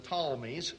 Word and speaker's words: Ptolemies: There was the kingdom of the Ptolemies, spelled with a P, P-T-O-L-M-E-Y, that was established, centered in Egypt, down in Alexandria, named after Alexander himself.